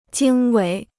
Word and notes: 经纬: jīng wěi

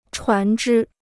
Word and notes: 船只: chuán zhī

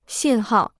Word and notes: 信号: xìn hào

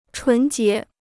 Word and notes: 纯洁: chún jié

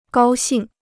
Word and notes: 高兴: gāo xìng

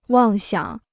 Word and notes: 妄想: wàng xiǎng

